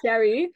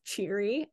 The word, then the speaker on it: Che'ri: Yes, she's Sherry. Mark Thompson, who does the audiobook, says cheery, and I just physically cringe every time he says that because it's like Sherry, Sherry.